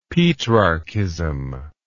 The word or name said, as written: petrarchism